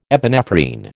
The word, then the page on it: epinephrine: American English